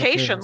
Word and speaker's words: Cation: It's Cation, like staycation.